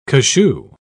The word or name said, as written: cachou